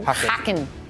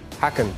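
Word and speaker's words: Häcken: Roma with the two one win over Sheriff Bayer Leverkusen, also taking care of business four 0 over Hacken. Did I say that right? Mm-hmm. Hacken. Hacken. I like it. It's a fun Swedish, one.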